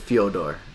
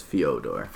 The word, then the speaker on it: Fyodor: Sorry, it's Fyodor. That was a Fyodor? – Not with a U, it's Fyodor.